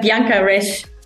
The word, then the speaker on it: Bianca Rech: It's like Bianca Rech.